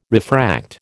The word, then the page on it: refract: American English